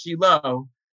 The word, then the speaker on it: Shiloh: And we're going to see, if you just look at the words, Shin Lamed Hey, it is pronounced Shiloh.